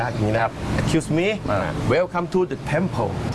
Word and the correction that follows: temple: Welcome to the temple. – เขาบอกว่ายินดีต้อนรับเข้าสู่วัดนะครับท่านออกเสียงถูกแล้วครับ temple. ตัว L ครับ pronunciation ตัว L temple.